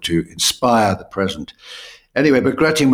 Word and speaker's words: Bagration: Yeah, so this is something that's not, I think it's pronounced Bagration. – Bagration.